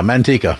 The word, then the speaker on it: Manteca: Yeah, Manteca.